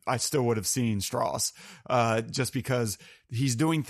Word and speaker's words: Strauss: Um, and you really just see Oppenheimer, uh, and doubly so for Robert Downey Jr. as Strauss it's it's pronounced Strauss. – It's the Southern way. – like, yeah, yeah, yeah. Did I little, did I pronounce it yeah, wrong or right? I, I, was like, I'm just I, commenting I, on. I know it's not Strauss, which is how it's kind of spelled.